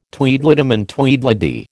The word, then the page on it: Tweedledum and Tweedledee: American English